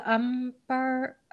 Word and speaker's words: Umbara: How do you say the planet? The Umbara. – Oh, Umbara.